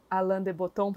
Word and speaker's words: Alain de Botton: Você falou que você também leu, mas a gente acabou não trocando opiniões que é o do Alain de Botton, provavelmente eu falei errado o nome dele, que é a arte de é. – viajar. Não, é assim mesmo.